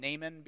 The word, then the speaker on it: Naaman: And so if you'll turn with me to 2 Kings chapter 5, we're going to journey through this story of the guy's name is actually pronounced Naaman. I'm just going to call him Naaman because that's how most of you are probably going to read it as you see it.